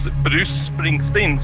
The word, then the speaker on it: Bruce Springsteen: Det uttalas Bruce Springsteen, så att nästan så att C:n och S:n är nästan samma.